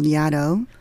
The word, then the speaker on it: Yaddo: And then after that, I went to this residency called Yaddo, which is supposed to rhyme with shadow. And that's how the name, somebody made up that name, and they thought it was funny that it rhymed with shadow.